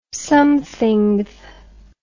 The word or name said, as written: somethingth